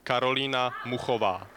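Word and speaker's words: Karolína Muchová: So, for his benefit and indeed all our benefit, just very clearly and slowly, the correct pronunciation of Karolina, whatever, is. – It's Karolina Muhova.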